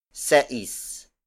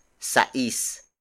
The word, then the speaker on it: sais: Instead, most Filipinos use sais. Sais. Sais. Sais is spelled as S-A-I-S.